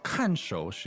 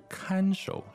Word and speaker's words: 看守: 那我问一下，这个不是看守，是看守是吧？ – 对，这个是多音字，大家注意，这里念看。